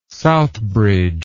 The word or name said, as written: Southbridge